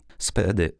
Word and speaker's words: SPDY: W uproszczeniu obsługi tak wielkiej ilości danych ma pomóc nowy protokół SPDY wymawiaj. SPDY, szybki, stworzony przez Google.